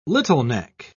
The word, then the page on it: littleneck: British English